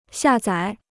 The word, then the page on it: 下载: xià zǎi